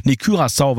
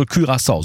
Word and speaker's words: Curaçao: Curaçao, weil es glaube ich niederländisch ist, ne? – Ja, richtig, ja, okay. Das ist richtig. – Also Curaçao, so spricht man es aus.